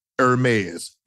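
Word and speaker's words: Hermès: Hermes.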